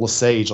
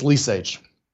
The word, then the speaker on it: Lesage: The only people who pronounce it Lesage are people from there. Everybody else pronounces it Lesage, like some fancy French shit. It ain't, it's Lesage.